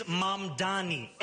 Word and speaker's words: Mamdani: And furthermore, the name is Mamdani. M-A-M-D-A-N-I. You should learn how to say it because we got to get it right.